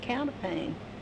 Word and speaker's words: counterpane: Yeah, they used to call it counterpane. – Okay. – Or counterpane, we called it. – Uh-huh. – But it's spelled pain, counterpane.